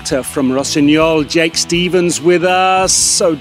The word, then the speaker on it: Rossignol: Yeah, it would be Rossignol.